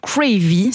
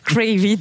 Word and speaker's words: Cravy: Et oui, l'enseigne lance Cravy, si c'est bien comme ça qu'on prononce. – C'est très bien, quel accent. – Cravy, donc c'est un concept de restauration rapide qui est installé directement dans les magasins Deleuze.